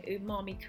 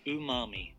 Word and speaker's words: umami: I wanted to make sure that I was pronouncing umami correctly. So this is the pronunciation. – Umami. – Umami, there we go.